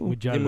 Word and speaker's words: Mugello: Mugello. Mugello.